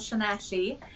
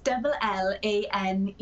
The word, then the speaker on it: Llanelli: I live in a town called Llanelli.